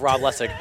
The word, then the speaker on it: Rob Lessig: Hi, I'm Rob Lessig. – Can you spell your first and last name? – R-O-B-L-E-S-S-I-G.